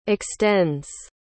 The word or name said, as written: extends